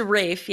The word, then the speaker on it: Ralph: Is it not Rafe Fiennes? – It's Rafe, yeah. – Yeah, I would say I've heard. – But it's spelled that way. It's spelled like Ralph, but it's pronounced Rafe.